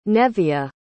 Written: Neviah

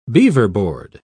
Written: beaverboard

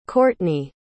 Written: kortney

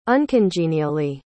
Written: uncongenially

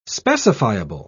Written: specifiable

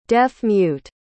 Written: deaf-mute

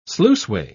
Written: sluiceway

